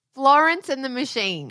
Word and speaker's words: Florence and the Machine: I don't know if you've heard of a little band called Florence and the Machon. – What are you saying? – Florence. I actually can't say it now. I can't say her name. Florence and the Machine.